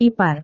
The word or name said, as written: ipar